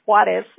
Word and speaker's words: Juarez: And I'm like, no, no, you don't pronounce the J. You don't pronounce the J. It's Juarez. It's, oh my gosh.